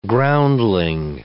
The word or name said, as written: groundling